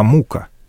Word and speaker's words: мука: Крестьяне говорили не мука, а мука, ставя ударение искусственно иначе, подчеркивая, насколько тяжек труд хлебороба.